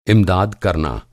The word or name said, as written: इमदाद